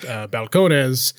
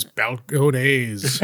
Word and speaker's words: Balcones: always supposed to be pronounced Balcones. – Yeah. – And the white people decided it was Balcones. Balcones. Mayonnaise you would eat on a balcony.